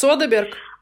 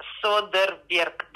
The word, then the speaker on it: Содерберг: Содерберг? – А, Содерберг, да.